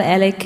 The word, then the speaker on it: Alig: Some say promoter Michael Alec killed the angel Melendez.